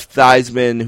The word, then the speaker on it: Theismann: I mean, you have Theismann, who's the guy who went to Notre Dame and, like, changed his name to rhyme with his Heisman gimmick thing, and has this horrific injury on TV.